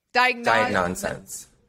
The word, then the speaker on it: Diagnosense: Am I saying that right? – Diagnosense. – Diagnosense. – Just so like nonsense.